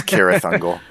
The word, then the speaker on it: Cirith Ungol: I think Tolkien pronounced it Cirith Ungol, but the band pronounced it Cirith Ungol. – Correct. So yeah, it's Cirith Ungol. I am a huge Tolkien nerd, and that is how it's properly pronounced.